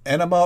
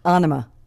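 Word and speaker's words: Ænima: Is it pronounced Enema or Anima? – Anima.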